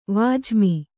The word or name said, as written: ವಾಜಿಮಿ